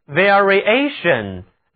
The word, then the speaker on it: variation: 如果把名词后缀 ation 连在词根 vari 后面，就构成了名词变化、变动，读作 variation，它的重音在第二个音节 ation 上面。